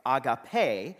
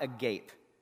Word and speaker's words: agape: It's described by the word agape. That's how you pronounce it in the Greek. We have known it as agape. You can pronounce it any way you want. It's just not agape, right? That's a whole different thing.